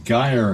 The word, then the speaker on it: gyre: Make the G in gyre and gimble hard.